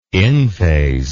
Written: in phase

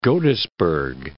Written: Godesberg

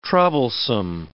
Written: troublesome

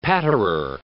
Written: patterer

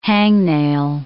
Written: hangnail

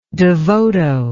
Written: devoto

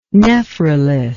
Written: nephrolith

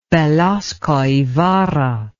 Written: Velasco Ibarra

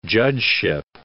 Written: judgeship

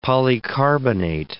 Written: polycarbonate

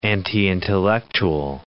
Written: anti-intellectual

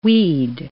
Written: we'd

